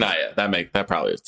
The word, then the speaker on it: Saniyya: Sania Sidney. – I think it's Sanaya. I actually have Sanaya. That sounds more right.